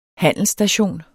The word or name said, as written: handelsstation